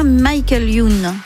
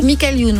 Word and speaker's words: Michael Youn: Est-ce que 73, c'est l'année de naissance du chanteur, humoriste et comédien Michael Youn ? – Michael Youn, oui. C'est vrai, je sais pas pourquoi je voulais prononcer en américain.